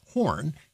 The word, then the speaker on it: Hoorn: They were from Horn, a city in the Netherlands, and that's spelled H-O-O-R-N in Dutch, but is pronounced Horn, hence Cape Horn.